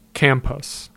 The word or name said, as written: Campus